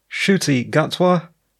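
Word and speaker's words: Ncuti Gatwa: After the story, Shuti Gatwa will take over as the Doctor sometime next year. And yes, I am now pronouncing his name correctly.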